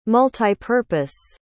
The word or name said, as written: multipurpose